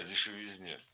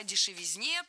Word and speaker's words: дешевизна: Кстати, о дешевизне. Самый дешевый автомобиль, как известно, по-моему, долларов 400 он стоил. – Кстати, о дешевизне прозвучало не кстати.